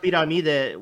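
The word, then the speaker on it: pirámide: it's pronounced piramide, which Spanish is pyramid, but yeah.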